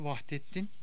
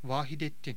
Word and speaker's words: Vahidettin: Son sultanın adı Vahdettin değil, Vahidettin.